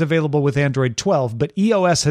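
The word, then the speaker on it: /e/OS: Yeah, they're not pronounced though. So we're just going to say EOS.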